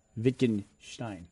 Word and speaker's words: Wittgenstein: Someone's correcting me on my pronunciation of Wittgenstein. They said it's pronounced... Wittgenstein. – Stein? – Oh.